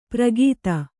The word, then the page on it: ಪ್ರಗೀತ: pragīta